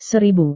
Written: seribu

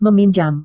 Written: meminjam

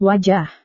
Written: wajah

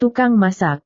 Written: tukang masak

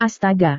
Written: astaga

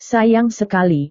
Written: sayang sekali

